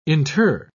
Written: inter

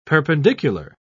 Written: perpendicular

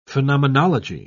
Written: phenomenology

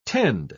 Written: tend

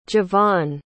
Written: jevon

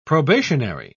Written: probationary

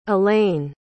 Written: ellaine

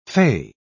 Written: fay

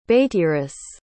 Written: beitiris

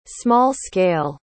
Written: small-scale

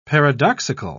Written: paradoxical

